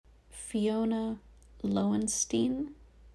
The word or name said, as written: Fiona Lowenstein